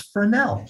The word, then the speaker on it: Fresnel: And Ian, thank you for your podcast for teaching me how to properly pronounce Fresnel. With the French silent S.